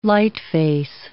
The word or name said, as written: lightface